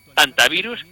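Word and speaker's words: hantavirus: antivirus, por cierto, que se pronuncia en español, normal, sin la hecha aspirada, estamos hablando español, no estamos haciendo un término anglosajón.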